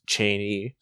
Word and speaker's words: Cheney: Matt Cheney, who corrected me on how to pronounce his name. It's Cheney.